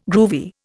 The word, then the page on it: groovy: Indian